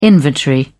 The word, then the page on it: inventory: British English